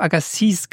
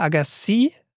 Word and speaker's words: Agassiz: Er schreibt: Kleines Nitpicking, der Name von Louis Agassiz, wie ich es in der Folge gesagt habe, den spricht man ohne S am Ende. Also ich habe Agassiz gesagt, weil es schreibt sich, der Nachname schreibt sich mit einem Z am Ende. Aber dieses Z spricht man nicht, also eher Agassiz.